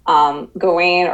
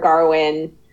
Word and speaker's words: Gawain: Really, besides him being just like a fantastic actor, he really sells Gawain or Garwin. It's Arthurian English. – Funny is that the characters pronounce it different ways too. King Arthur says Garwin, and most other people say Gawain in the movie.